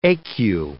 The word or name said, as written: ecu